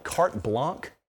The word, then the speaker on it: carte blanche: CBS gives us, what do you call it, carte blanche, even though it's spelled like Blanche?